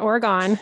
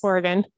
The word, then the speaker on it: Oregon: And then by 1990, things were pretty tough and it was hard selling Oregon wine. They would travel around the country and they'd take a map and they say, here's Oregon. It's not Oregon, it's Oregon.